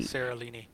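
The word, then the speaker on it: Séralini: And so I think with Cerulli, is that how you say his name? Yeah, he Seralini.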